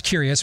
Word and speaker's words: Cureus: It's pronounced Curious, but it is spelled C-U-R-E-U-S. So it's spelled differently.